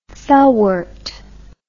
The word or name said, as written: felwort